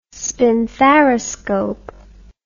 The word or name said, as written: spinthariscope